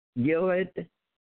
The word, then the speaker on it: Gilead: Gilead, pronounce his name, the father's name. Can you pronounce the father's name? Huh? – Gilead. – Okay, Gilead. So I did pronounce it right.